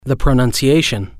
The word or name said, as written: the pronunciation